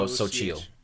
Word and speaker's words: Xochitl: Xochitl Gomez? – Oh, Xochitl.